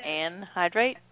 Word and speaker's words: anhydrite: No, they actually do, but so how do you say that? Anhydrite? – Anhydrite is how.